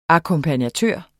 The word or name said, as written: akkompagnatør